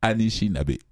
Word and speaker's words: Anishinaabe: Hein, Maurice, je tombe bien, hein? J'ai pas mal prononcé. Anishinaabe. – Bravo.